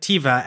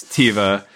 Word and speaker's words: Teva: And you would not be alone in your pronunciation of it as Teva. You are allowed to pronounce it as you wish.